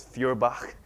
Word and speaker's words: Feuerbach: It's Feuerbach, right? You got to have that guttural at the end.